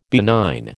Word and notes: benign: American English

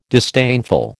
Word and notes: disdainful: American English

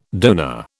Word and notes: donor: British English

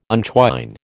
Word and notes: untwine: American English

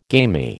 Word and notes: gamy: American English

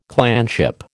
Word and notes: clanship: American English

